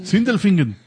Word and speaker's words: Sindelfingen: Sindelfingen.